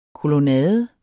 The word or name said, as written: kolonnade